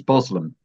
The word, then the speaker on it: Burslem: And the local pronunciation is Boslem. But if an outsider came in and said Boslem, he would be told, or she would be told, that you can't say that.